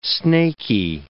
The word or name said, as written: snakey